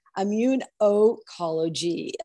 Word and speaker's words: Immunocologie: Immunocology.